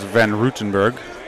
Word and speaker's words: Van Rutenberg: The last name is Van Rutenberg. – See? – Or you can say it in Dutch, where I was born in Holland.